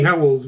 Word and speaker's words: Howells: Tony Howells with an S.